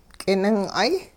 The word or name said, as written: k'ining'ay